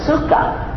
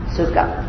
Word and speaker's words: sukkah: But the Jewish people were told to build a sukkah. Am I saying that right? Sukkah. Okay. Sukkah.